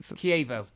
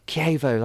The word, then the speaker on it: Chievo: He's been at Italian side, Chievo, is it? – Chievo. – Chievo, that's how you pronounce it.